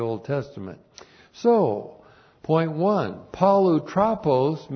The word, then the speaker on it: Polutropos: Polutropos. P-O-L-U-T-R-O-P, long O-S. Polutropos.